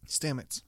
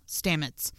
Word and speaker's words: Stamets: Stamets. – Stamets.